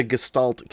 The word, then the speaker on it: gestalt: What the hell is a gestalt? Am I pronouncing that right? – You're pronouncing that correct.